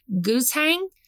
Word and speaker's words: guzheng: We have live music with one of our favorite customers, Jasmine, who's playing a traditional Chinese string instrument called the guzheng. I believe that's how you pronounce it. – Yep.